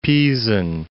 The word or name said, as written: Pisan